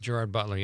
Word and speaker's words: Gerard Butler: It's, I think, Gerard Butler. That's how you say it, right? – Gerard Butler, yeah.